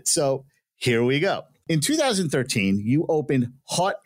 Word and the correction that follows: Haute: I think I pronounced that right. – No, you did not get it. It is French. It is Haute & Co. Bridal Boutique.